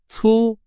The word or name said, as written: 粗